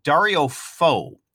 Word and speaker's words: Dario Fo: In the show notes, we've also provided a link to a YouTube example from Dario Fo.